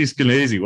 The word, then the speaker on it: Skenazy: It happens to rhyme with crazy. So there you have it. – Crazy. – In a nutshell. No. Literally. – America's Worst Mom, Crazy Skenazy.